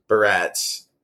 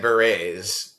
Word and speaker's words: barrettes: But yeah, I remember you and I were going like rapid fire, and then yeah, you showed Michelle how to do barrettes, and we kept pronouncing it berets.